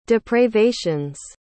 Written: depravations

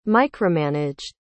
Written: micromanaged

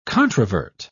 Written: controvert

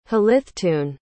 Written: hlithtun

